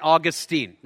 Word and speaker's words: Augustine: St. Augustine, right? It's pronounced Augustine is actually the man's name.